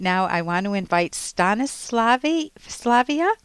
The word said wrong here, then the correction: Stanislava: Did I say that right? – Yes, Stanislava. – Oh, Stanislava. Okay.